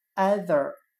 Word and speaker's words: either: 今天所学习的单词 either，e i t h e r，两者中的任何一个，我们就可以通过它的发音 either 联想到汉语的挨的。